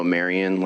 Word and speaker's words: Marion: Mr. Chairman, members of the committee, for the record, my name is Noah Marion, last name is spelled M-A-R-I-O-N, here representing Wild Montana today.